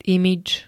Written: imidž

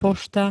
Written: pošta

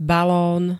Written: balón

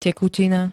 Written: tekutina

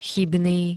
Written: chybný

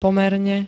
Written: pomerne

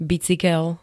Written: bicykel

